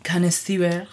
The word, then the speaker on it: connoisseur: I'm pretty sure this game was made for people that are wine connoisseurs. Did I say that right? – Connoisseur. – Connoisseur.